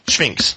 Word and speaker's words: Sphinx: How do you pronounce that? – Sphinx. – Sphinx. – Sphinx. Yeah. Sphinx. You know, it's like the Egyptian Sphinx.